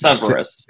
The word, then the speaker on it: Severus: How did you say his name? – Severus? Severus? Severus. It's Severus.